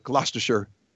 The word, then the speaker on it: Gloucestershire: Gloucestershire is the name of the town. Looks like Gloucester with the Shire on the end of it, but they kind of leave a couple syllables out. It's Gloucestershire.